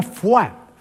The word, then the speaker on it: foie: Fua, que es una palabra francesa que a mí siempre me ha llamado mucho la atención porque se escribe foie, ¿sí?, y se pronuncia fua. Fíjate, la O, la I y la E se escribe y luego se pronuncia con la U.